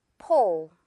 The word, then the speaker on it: pull: 우리 한국말 할 때처럼 폴이라고 포우입니다. 자, 듣고 따라해 주세요. 포우.